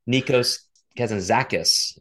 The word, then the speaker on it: Nikos Kazantzakis: I wanted to make sure I knew how to pronounce his name. It's Nikos Kazantzakis, a kind of a modern 20th century Greek philosopher.